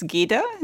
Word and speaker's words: Gyda: one of the wives is Svanhild, but the one I would like to bring up at the moment is Gyda. Is that how you would pronounce it? – Yes.